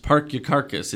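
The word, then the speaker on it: Parkyakarkus: I must confess, Matt, I owe you an apology for Parkiacarcus, pronounced Parkiacarcus.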